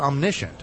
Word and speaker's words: omniscient: So another quality of God, the Father, and I mentioned it, I said it wrongly before, but it's omniscient, mm-hmm.